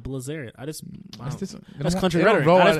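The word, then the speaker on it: Bilzerian: Dan Brazilian. That lifestyle. What a goat. That's the lifestyle. – You know, we're not even saying his name right. – Dan Blazarian.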